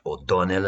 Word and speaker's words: O'Donnell: They were basically generals, names like Narvaez, Serrano, and O'Donnell, pronounced O'Donnell in Spanish.